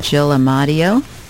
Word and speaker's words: Jill Amadio: I am here with Jill Amadio.